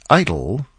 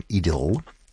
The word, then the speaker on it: idyll: An idyll, or if you prefer the English pronunciation, an idyll.